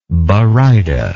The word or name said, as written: barytic